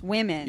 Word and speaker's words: women: It's like an I. It's like a short I. Like, when. It's women.